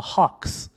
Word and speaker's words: Hawks: 那么我举个例子吧，就一般我们说老鹰队，英文的话或者说美式的这个发音呢，叫做 Hawks，就是这么一个感觉的。然后呢，米尔斯的这一个老鹰的发音就是非常澳大利亚乡村风的，他应该是把它发成了 Hawks。就是大家就能听出这个区别了，对吧？Atlanta Hawks 还有 Atlanta Hooks。